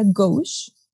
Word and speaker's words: gauche: una palabra primero, este, que es gauche, se escribe gauche, pero se pronuncia gauche.